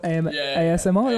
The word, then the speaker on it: ASMR: ASMR, am I saying that right? – Yeah.